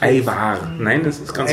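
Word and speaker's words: Ajvar: Eiwar, ich finde, das ist milder wie das Lutenitzer.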